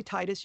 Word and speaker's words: Tidus: But a lot of people say Titus. You can say either one. The pronunciation they gave me when we made the game was Tidus, so I always say Tidus.